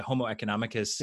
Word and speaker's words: homo economicus: homo economicus is that how you say it the homo economicus yeah.